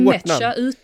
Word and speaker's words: Nmecha: Nmecha, Nmeka. jag vet inte. – Jag säger till med Mecha utan n-et, alltså att det är silent n.